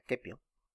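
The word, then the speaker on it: Caepio: En latín sería Quepio. Es muy curioso que sepamos que en el latín clásico la C tenía la pronunciación de la K.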